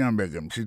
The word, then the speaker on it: hamburger: N'a pas remarqué que dans le mot hamburgers, comme tu as dit, il y a trois syllabes là-dedans, quand tu appuies et puis ça, c'est sur le premier syllabe là.